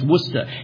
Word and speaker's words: Worcester: It's not that. It's Worcester. And if you ever say Worcester, they're going to laugh you out of town. It's Worcester, Massachusetts. We're going to Worcester, Massachusetts. That's how you say it.